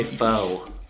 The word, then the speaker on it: pho: I don't know how to make pho or any of that stuff. Oh, I said it wrong. I'm sorry. I thought I was. – no, you said it, no.